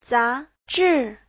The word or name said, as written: za2zhi4